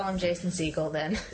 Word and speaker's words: Segel: Jason Segel. – Segal, like as in Steven Segal, is that how you pronounce his name? It's pronounced just like Steven Segal's last name.